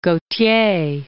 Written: Gautier

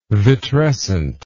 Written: vitrescent

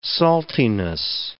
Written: saltiness

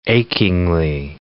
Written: achingly

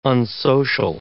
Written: unsocial

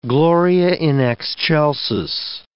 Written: Gloria in Excelsis

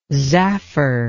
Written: zaffer

